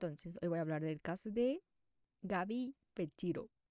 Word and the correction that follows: Petito: Petito. Se escribe petito así, en Spanish.